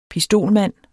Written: pistolmand

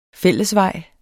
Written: fællesvej

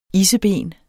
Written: isseben

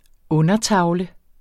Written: undertavle

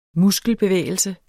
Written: muskelbevægelse

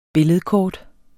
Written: billedkort

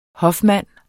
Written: hofmand

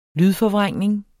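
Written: lydforvrængning

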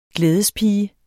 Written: glædespige

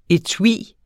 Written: etui

